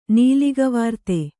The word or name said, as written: ನೀಲಿಗವಾರ್ತೆ